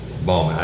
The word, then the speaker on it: Baughman: Baughman. I didn't know how to say it. It's spelled B-A-U-G-H-A-M? – B-A-U-G-H-M-A-N.